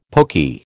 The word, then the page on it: pokey: American English